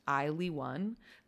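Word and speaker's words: ILY:1: It is spelled I-L-Y colon number one, but pronounced I-Ly-1. The name combines the pronoun I with the suffix L-Y to make a fictional adverb like I-Ly. – Right? I-Ly. – And the one represents coming together as one, but also I-L-Y is an acronym for I Love You.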